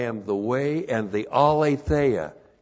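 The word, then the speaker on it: aletheia (ἀλήθεια): Alethenos is an adjective that is related to the noun, you know the noun, aletheia. A-L, long E-T-H-E-I-A.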